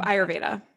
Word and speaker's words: Ayurveda: Ayurveda dosha, right? Is that how you say it? – Right. Yep. Ayurveda.